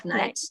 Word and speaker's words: NAIT: Nate. Is that how you say it? The N A I T. yeah, Nate, Nate, Nate, yep.